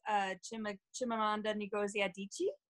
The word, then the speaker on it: Chimamanda Ngozi Adichie: But I have been reading books in Nigeria because I fell in love with Chimamanda Ngozi Adichie. I don't know if you guys have read any of her work. – Yeah, I've seen the name, and you pronounced it perfectly.